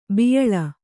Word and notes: ಬಾಯಳಿ: bāyaḷi

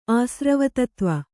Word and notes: ಆಸ್ರವತತ್ವ: kannada

